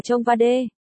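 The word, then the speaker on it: Cheongwadae: Phủ Tổng thống Hàn Quốc hay còn được gọi là nhà xanh mà theo tiếng Hàn đọc là Chongwa-dae. Chongwa-dae, Blue House, Phủ Tổng thống nằm ở quận Jongno, Seoul, bao gồm tòa nhà văn phòng chính và những khu nhà phụ.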